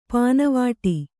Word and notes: ಪಾನವಾಟಿ: pāna vāṭi

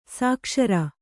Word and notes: ಸುಕೇಸರ: sukēsara; kannada

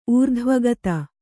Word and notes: ಊರ್ಧ್ವಗತಿ: ūrdhvagati

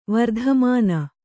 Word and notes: ವರ್ತಮಾನ: vartamāna

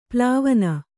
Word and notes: ಪಾಲ್ವಣ: pālvaṇa

